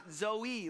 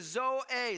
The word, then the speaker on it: Zoe: Some people pronounce it Zoe, like it's a 4-year-old girl, you know? But the correct way we pronounce this is Zoe. Say it out loud. Zoe. Zoe, okay? That's how we pronounce it.